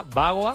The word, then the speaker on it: Bagoa: Bagoa, con B de burro, con su Bagoa. Acento en la A. Bagoa.